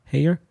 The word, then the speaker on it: Heyer: Heather, how do you say it? Heather Heyer? H-E-Y-E-R? Heyer?